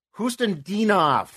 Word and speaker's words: Khusnutdinov: It's funny you asked that because I actually wrote down the phonetic pronunciation. Houston Dinoff. – So the K doesn't count.